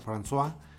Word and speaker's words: François: Y pues realmente se escribía francoise, ¿no? Entonces francoise se pronuncia François.